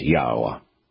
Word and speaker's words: Yahweh: The O is from the Hebrew Wah, the third letter in Yah's name. The Yod and the Wah are both vowels in ancient Hebrew. The very fact that we can pronounce Zionis tells us that we can pronounce Yah's name, and we know that it is Yahweh.